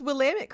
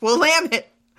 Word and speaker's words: Willamette: Oh my gosh, you pronounced Willamette correctly. – I know. It's because of all the times that I fucked it up. And you were like, it's not this, it's Willamette.